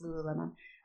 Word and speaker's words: Lululemon: One of them is my yoga bag, so it's filled with the best stretches, Lululemon.